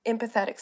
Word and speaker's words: empathetic: but I do see your empathetic self mm. growing. – And em- I s- empathetic. Stress on the M.